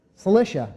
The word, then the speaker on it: Cilicia: Cilicia.